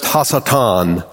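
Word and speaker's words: Hasatan (השטן): In Hebrew, the word Satan is pronounced Hasatan, and it is a noun that means the adversary or the accuser.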